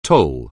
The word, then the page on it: toll: anglais